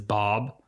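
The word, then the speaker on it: Baab: Okay, I thought it was B-O-B. – Well, it's pronounced B-O-B, but it's B-A-A-B.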